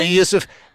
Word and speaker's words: Yusuf: I hope I'm pronouncing that correctly. – Yeah, Yusuf, another mega talent.